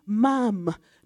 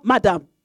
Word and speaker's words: ma'am: We say, you pronounce it ma'am. Not as in, you pronounce it like you're saying jam. Not madam. You say ma'am.